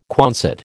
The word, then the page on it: Quonset: American English